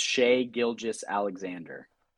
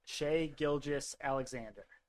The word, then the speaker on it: Shai Gilgeous-Alexander: You might have sold me, but you have to say his name right first. It's Shai Gilgeous-Alexander. – Shai Gilgeous-Alexander.